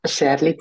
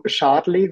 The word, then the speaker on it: Schärli: ähm, mein Name ist Andrina Scherli zum Nachname. es ist immer so Charlie, weil die Umlaut Umlaute kann man ja schlecht aussprechen so im Ausland.